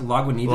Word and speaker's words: Lagunitas: Is it how you pronounce it? Lagunitas. – Lagunitas.